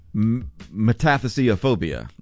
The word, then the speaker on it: metathesiophobia: Psychiatrists are saying that they're seeing an increasing number of patients suffering from metathesiophobia.